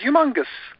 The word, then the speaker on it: Youmongus: I came up with a creative name called Humongous. It's spelled Y-O-U-M-O-N-G-U-S.